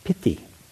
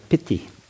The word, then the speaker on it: piti: But the Pali pronunciation is piti, piti, kind of like we say pity, but piti, and it means usually translated as joy.